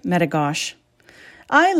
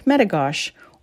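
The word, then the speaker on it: Metigoshe: Lake Metigosh. I live on Turtle Mountain, straddling the international border with nearly 1,600 acres of pure beauty surrounding me. I'm Lake Metigosh, or as the Americans say, Lake Metigoshi.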